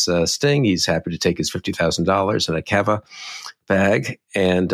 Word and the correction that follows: Cava: Cava, I believe we're pronouncing Cava. What did I say? Cava. – Yeah, like Cava.